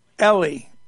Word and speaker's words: Eli: Officer Rick, the prosecutor's name is pronounced Ellie, like the girl's name.